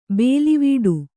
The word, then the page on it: ಬೇಲಿವೀಡು: bēlivīḍu